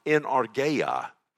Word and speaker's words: energeia: In the Greek, it would be pronounced energeia.